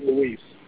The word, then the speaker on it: Luis: Or is it spelled like Louis and he pronounces it Luis? – I think he pronounces it Luis. But it's L-U-I-S.